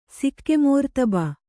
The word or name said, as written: ಸಿಕ್ಕೆಮೋರ್ತಬ